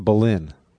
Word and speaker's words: Boleyn: It's Boleyn. It's pronounced Boleyn. – Boleyn? Let's go, Anne Boleyn. – No, it's Boleyn. Vowels are different in those days, so the spelling is different than the pronunciation.